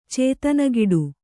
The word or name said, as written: ಚೇತನಗಿಡು